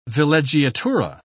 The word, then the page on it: villeggiatura: British English